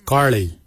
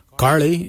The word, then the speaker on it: giilyi: Darb is spelled D-H-A-A-B. It is a traditional knife, a darb. For boomerang, we say karli. Karli is the traditional name for boomerang. Karli is spelled G-I-I-L-Y-I, karli.